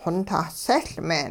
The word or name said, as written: xontah sehLme'n